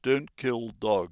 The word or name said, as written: kill dogs